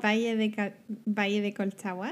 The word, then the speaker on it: Colchagua: so you, you don't really pronounce the G. – Colchagua, like the D is kind of you. You mean like the, yeah, yeah, yeah. You you don't. – So Colchagua.